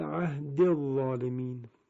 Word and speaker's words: عهدی الظالمین: اگر شما به مصحف مراجعه بفرمایید میبینید که نوشته شده قال لا ینال عهدی الظالمین، اما وقتی وصل میکنیم چون اون یا اضافه آخر کلمه عهدی به صورت ساکن تلفظ میشه، موقع وصل دیگه نمودی نداره مگر اینکه ما وقف کنیم بر اون کلمه و بخوانیم عهدی، اما در وصل میشود عهد الظالمین.